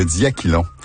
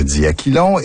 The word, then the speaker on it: diachylon: En principe, il faudrait dire diachylon, mais la prononciation diachylon est acceptable.